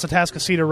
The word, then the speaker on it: Atascocita: And by the way, he did pronounce Atascocita right.